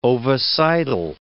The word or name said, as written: ovicidal